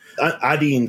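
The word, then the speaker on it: Adiane: Adine, because she's Adinos and the Undine. So right. I think it's Adine is how they pronounce it.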